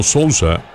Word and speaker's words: Sousa: Y yo cada vez que veo a estos pitchers o a estos jugadores con el apellido Sousa, lo pronuncio en español, por eso. Hay algunos que lo pronuncian en inglés.